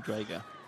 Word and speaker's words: Drago: I don't like that you call him Drago. – Why? That's how you pronounce it.